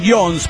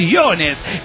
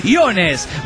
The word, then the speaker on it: Iones: Pero me dicen Jones porque a nadie le da la cabeza para decir Iones, que es muy complicado. Bueno, que simplemente se pronuncia como se escribe, Iones.